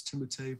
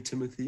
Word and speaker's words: Timothée: He said technically, technically it's Timothée, but you can call me Timothy.